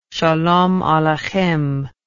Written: shalom aleichem

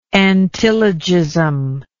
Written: antilogism

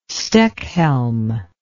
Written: stechhelm

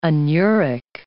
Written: anuric

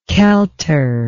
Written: kelter

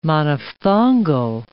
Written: monophthongal

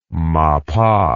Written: mappah

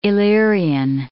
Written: Illyrian

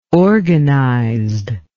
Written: organized

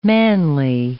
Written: manly

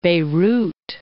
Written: Beirut

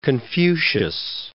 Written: Confucius

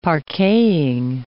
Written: parqueting

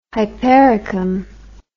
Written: hypericum

